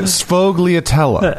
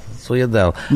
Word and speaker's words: Sfogliatella: let me just try it. Sfogliatella. – That's it. – Okay. – All right. Sfogliatella.